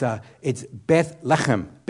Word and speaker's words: Beth Lechem: It's Beth Lechem.